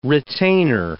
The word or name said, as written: retainer